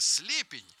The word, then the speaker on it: слепень: Лингвист Кузнецова, вот уж неожиданное открытие, заявила, что ударение в слове «слепень» скоро будут ставить на первый слог.